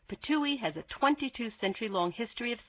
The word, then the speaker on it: Ptuj: Purse your lips and make a spitting sound like this. Ptui. That's the name. It's spelled P-T-U-J, but it's pronounced Ptui, and it's in Slovenia, the northwestern part of the former Yugoslavia.